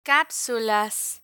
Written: capsulas

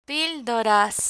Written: pildoras

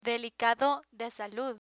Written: delicado de salud

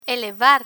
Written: elevar

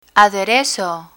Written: aderezo

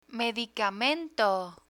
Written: medicamento

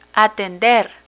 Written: atender

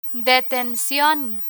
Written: detencion

